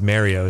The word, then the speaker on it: Mario: Is it Mario? Or I always thought it's Mario. – I think it's Mario is how he pronounces it. – People in New York say Mario. – Mario. – Or like Italian. – I used to say Mario for him, and then I was corrected, and it was said it's Mario.